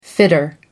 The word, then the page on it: fitter: American English